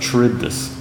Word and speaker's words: Charybdis: There is the great ancient Greek myth, or actually based in reality, of the rocks of Scylla and the whirlpool of Charybdis. That's how I pronounce it. I won't give you the, you know. You may pronounce it differently.